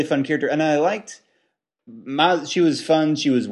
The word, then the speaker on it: Maz: Should we throw Maze in there? Maz, how do you say? – I liked Maz a lot. – Maz. – I can never get her name right. Maz. Thank you, Zach, from the other room.